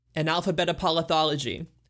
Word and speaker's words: Analphabetapolothology: Yes, my classic pick is Analphabetapolothology, the 1998 compilation by foundational Midwest emo band Cap'n Jazz. – Bravo on the pronunciation.